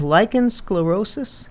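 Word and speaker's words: lichen sclerosus: This woman said, I know of two people in Brookings with lichen sclerosis. Is that the correct pronunciation? – That's the correct pronunciation, yes.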